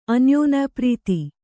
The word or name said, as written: ಅನ್ಯೋನ್ಯಪ್ರೀತಿ